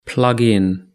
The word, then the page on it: plugin: British English